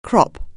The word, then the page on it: crop: anglais